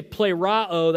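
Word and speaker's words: plerao (πληρόω): So that word fulfill in the Greek is, you say plerao, that's how you pronounce it, and plerao is used 17 times in the Gospel of Matthew.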